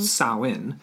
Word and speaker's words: Samhain: pronounced Samhain.